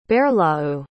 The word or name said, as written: baerhloew